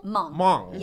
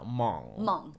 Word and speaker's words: Hmong: Half Hmong? – Hmong. Yes. – I always know how to say that. Hmong. Okay. – It's just silent H.